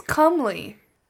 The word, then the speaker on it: comely: It's comely. Like the word come and then Lee.